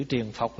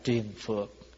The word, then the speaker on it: triền phược: À, chữ triền phọc là người Bắc nói, còn nói theo người miền Nam là triền phược.